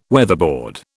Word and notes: weatherboard: British English

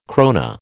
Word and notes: krona: American English

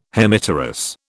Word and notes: Hemipterous: British English

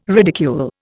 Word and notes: ridicule: Indian

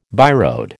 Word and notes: byroad: American English

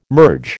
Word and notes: merge: American English

